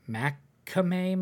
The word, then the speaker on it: McCamey: It's, I can't pronounce it. Macame Manor, MC, big M, little C, capital K, AMEY Manor.